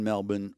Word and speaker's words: Melbourne: And she goes, well, I'm over in Melbourne. I said Melbourne because I was in Australia. Melbourne. – Oh, is that how they say it in Australia? – Yeah, they call it Melbourne.